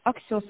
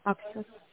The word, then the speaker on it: Axios: Axios, вот правильно ударение. Axios.